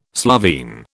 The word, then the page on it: Slovene: British English